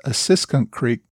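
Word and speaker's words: Assunpink Creek: It's brought to my attention that last week I referred to Assunkunk Creek. The correct pronunciation, I am told, is Assiskunk Creek.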